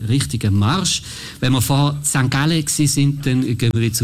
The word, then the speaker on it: St. Gallen: Ja, für alle im Bhutan oder irgendwo, wo es hört, so spricht man St. Gallen aus.